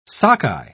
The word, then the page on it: sockeye: British English